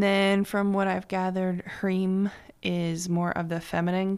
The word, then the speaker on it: Hrim: Which is, and Hrim, you probably know Om is spelled O-M, and Hrim is spelled H-R-I-M, pronounced Hrim.